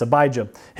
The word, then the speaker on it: Abijah: of Abijah, I think is how you say it. Yes, Abijah.